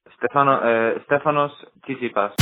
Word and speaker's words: Stefanos Tsitsipas: Stefanos Tsitsipas.